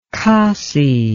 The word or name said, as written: kasi